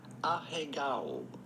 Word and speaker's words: ahegao: Okay, so the Japanese pronunciation is manga term ahegao.